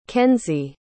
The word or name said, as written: kenzie